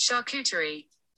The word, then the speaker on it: charcuterie: How do you say charcuterie? – That's pronounced charcuterie.